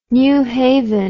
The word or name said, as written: new haven